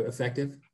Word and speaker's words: Effectv: And I hope I'm saying this right because there's no I in there. So I want to make sure I'm saying it right. Effectv. It's formerly known as Comcast Spotlight. But Lisa, how are you? – I am doing great today. Thank you so much for having me on. – I said it right too. Effectv? – You did.